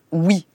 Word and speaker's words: Huy: Juette habitait une petite ville qui s'appelle Huy, H-U-Y. Et là-bas, on prononce oui, le U, c'est où.